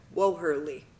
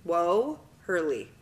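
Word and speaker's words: Woehrle: So if I say it like this, because this is how all my teachers pronounced it, see if you can do it. Whoa, Hurley. – W-U? – Whoa, Hurley.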